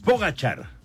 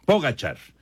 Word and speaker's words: Pogačar: Es Pogačar. Ya nos decía Memo García y ya lo estábamos checando también acá en diferentes páginas. Pogačar. La pronunciación okay, correcta. Okay.